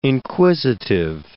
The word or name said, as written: inquisitive